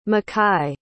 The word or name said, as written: macai